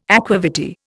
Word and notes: aqua vitae: Indian

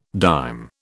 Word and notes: dime: British English

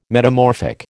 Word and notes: metamorphic: American English

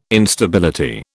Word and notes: Instability: British English

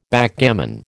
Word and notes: Backgammon: American English

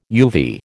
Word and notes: uvea: American English

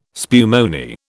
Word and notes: spumoni: British English

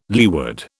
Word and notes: leeward: British English

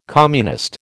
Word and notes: communist: American English

